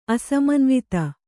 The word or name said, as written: ಅಸಮನ್ವಿತ